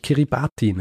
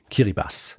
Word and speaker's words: Kiribati: und unter anderem einen kleinen Inselstaat, den ich Kiribati nenne. Ja? Tatsächlich spricht man das TI in dieser Sprache wie ein Doppel-S aus. Also eigentlich heißt es Kiribati.